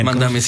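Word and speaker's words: Mamdani: Mandami, o nunca pronuncio bien. – Mandami, sí.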